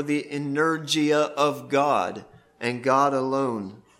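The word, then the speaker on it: energeia: Strong's tells us that the Greek word for it is, if I can pronounce it, energia. Energia. Think energy, okay?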